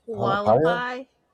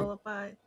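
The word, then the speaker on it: Hualapai: Walapai. – Yeah. You said it right. Walapai.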